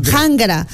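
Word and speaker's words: qanra: Jangra. – Pero se escribe con la Q. Q-A-N-R-A. Jangra. – Se me va a dar embolia ahorita. – Así se escribe en quechua, pero se pronuncia jangra.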